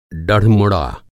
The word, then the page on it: डढ़मुड़ा: Hindi